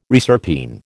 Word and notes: reserpine: American English

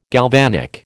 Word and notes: galvanic: American English

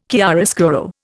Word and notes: chiaroscuro: Indian

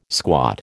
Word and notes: squat: American English

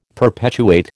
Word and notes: perpetuate: American English

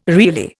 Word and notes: really: Indian

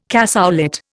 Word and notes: cassoulet: Indian

